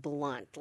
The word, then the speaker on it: Blount: It's spelled Blount, B-L-O-U-N-T, mm-hmm. but it's pronounced blunt, like a blunt edge.